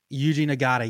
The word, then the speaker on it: Yuji Nagata: Yuji Nagata.